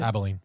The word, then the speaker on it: Abilene: Abilene.